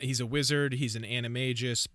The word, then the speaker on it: Animagus: How do you pronounce this? Animagus? Animagus?" – Um the correct pronunciation is animagus.